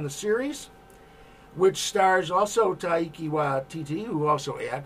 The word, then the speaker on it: Taika Waititi: Is cast now in the Taika Waititi pirate comedy. – Bravo on the pronunciation. Nailed it.